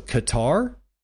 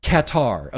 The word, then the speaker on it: Qatar: Henry GQJ with 900 says, Q-A-T-A-R, gutter, however we pronounce it. You were right the first time. I'll say, so Qatar? Think cat covered with tar. Qatar.